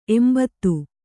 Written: ಎಂಭತ್ತು

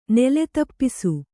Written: ನೆಲೆದಪ್ಪಿಸು

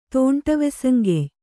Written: ತೋಂಟವೆಸಂಗೆಯ್

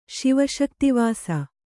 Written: ಶಿವಶಕ್ತಿವಾಸ

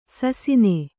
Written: ಶಾಸನ

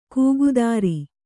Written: ಕೂಗುದಾರಿ

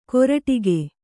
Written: ಕೊರಟಿಗೆ

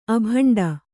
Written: ಅಬಂಡು

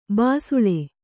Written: ಬಿಸಿಲ್

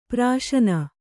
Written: ಪ್ರಶ್ನೆ

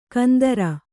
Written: ಕುಂದ್ರಿ

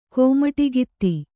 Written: ಕೋಮಟಿಗಿತ್ತಿ

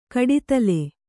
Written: ಕಡಿತಲೆ

